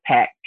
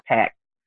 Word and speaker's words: Packt: They're pronounced PACT. P-A-C-K-T, but pronounced PACT.